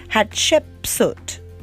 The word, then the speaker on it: Hatshepsut: Okay, say hat. – Hat. – Shep. – Shep. – Soot. – Soot. Hatshepsut, Pharaoh, born in 1508 and died in 1458 BC.